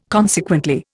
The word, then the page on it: consequently: Indian